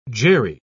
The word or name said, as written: Jerry